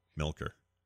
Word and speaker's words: Milker: Miller? – Oh, K.O. Milker? – Milker, not Miller.